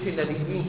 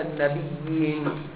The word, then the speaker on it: النبيين: ما تقولش النبيين كده لا النبيين، بتشدد الياء وتصل انها يا ايه مشاء واحد النبيين النبيين حييتم.